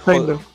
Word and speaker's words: Hoechlin: Y es que este actor, Tyler Hoechlin, o como se pronuncia. – David, no sé qué, ¿no? – No, Tyler Hoechlin.